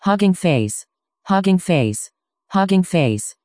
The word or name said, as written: huggingface